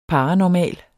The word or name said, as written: paranormal